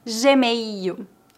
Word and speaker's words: Gmail: Lembre-se que no Brasil nós não pronunciamos o L em final de palavras. Em final de palavras, o L é pronunciado como U. E-mail, Gmail, Hotmail.